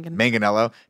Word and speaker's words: Manganiello: Also, I love that they were like, I'm just going to still frame here because they only got Joe how do you say his last name? – Manganiello to say like three lines.